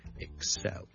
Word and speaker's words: excel: 个重音呢其实呢佢应该放喺 the second syllable 嘅。 So, excel. OK，同呢个字有关嘅一个字咧，我哋 excellent，to become excellent，OK，excellent、 okay?